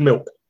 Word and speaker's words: MIWK: It's pronounced M-I-W-K. – Yeah. – I've been saying MOOC in my head for years, but I have now finally going to find out, is MOOC the correct pronunciation of MOOC? – I think any pronunciation is correct because it's a made-up word, but it's milk.